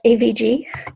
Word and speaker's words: AVG: Average, that's AVG.